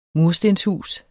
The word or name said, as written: murstenshus